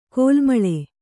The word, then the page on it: ಕಲುಮಳೆ: Kannada